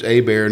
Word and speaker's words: Hebert: My last name's Hebert, and it's spelled H-E-B-E-R-T.